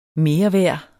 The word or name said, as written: mereværd